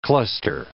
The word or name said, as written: cluster